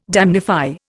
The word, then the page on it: damnify: Indian